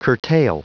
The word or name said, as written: curtail